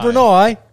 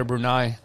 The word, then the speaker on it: Brunei: Brunei. – Blimey, we're going to Brunei. – Brunei, all right, that's the next place. – Blimey. – Hold on. Bucket list right there, Brunei.